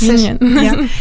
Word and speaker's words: Onegin: in Eugene Onegin, I think you pronounce Onegin.